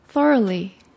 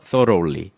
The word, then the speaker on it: thoroughly: 그래서 thoroughly. 그런데 뒤에 있는 gh라는 발음은 묵음이 돼서 사라진 거라고 보시면 되겠습니다.